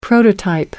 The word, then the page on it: prototype: American English